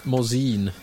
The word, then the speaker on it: Morzine: Morzine.